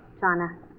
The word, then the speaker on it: Jana: I apologize for maybe mispronouncing your first name earlier. Johnna. – Johnna. – But it's spelled J-A-N-A, right? – Right.